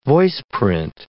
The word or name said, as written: voiceprint